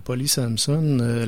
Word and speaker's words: Polly Samson: Mais la nouvelle viendrait de Polly Samson. C'est sûr que ça se prononce comme ça, étant donné que c'est une anglophone.